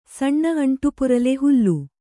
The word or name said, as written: ಸಣ್ಣ ಅಂಟುಪುರಲೆ ಹುಲ್ಲು